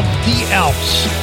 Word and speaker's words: Die Alps: They're called The Alps, but it's spelled Die Alps. D-I-E, capital A-L-P-S.